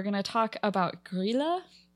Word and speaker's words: Grýla: Grýla. Pronounced like a K. Grýla. I just looked it up.